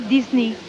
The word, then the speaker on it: Disney: parce qu'en anglais on dit Disney, Disney.